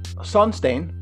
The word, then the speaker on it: Sonstein: And Sonstein is my indoctrination of the English word sunstone, pronounced in my native dialect. So instead of sunstone, it's Sonstein.